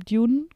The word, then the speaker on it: Dune: Also ich war ja jetzt im Kino diese Woche und habe Dune geguckt, den zweiten Teil. Habe ich es richtig ausgesprochen? – Ja.